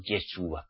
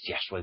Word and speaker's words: Yahshua: Hay que pronunciar su verdadero nombre. No Yeshua, como muchos decís, o Jesúa. Es Yahshua el punto.